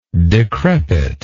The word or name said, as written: decrepitness